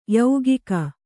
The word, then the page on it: ಯೌಗಿಕ: yaugika